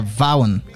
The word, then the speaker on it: Vaughan: Sabe a pronúncia? É Vaughan. – É? – Vaughan. – Jimmy Ray Vaughan. – Não pronuncia o G.